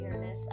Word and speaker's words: Uranus: And today's planet is Uranus, or a scientist might call it Uranus. – That's how I pronounce it. I pronounce it Uranus. I think you can pronounce it both ways, though.